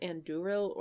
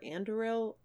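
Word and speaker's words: Andúril: Do you pronounce it Anduril or Anduril or... – In the movie, they say Anduril. – Okay. – But it feels like, with the emphasis, it should be Anduril, but...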